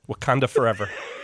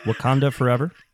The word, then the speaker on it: Wakanda Forever: Wakanda Forever. – Wakanda Forever.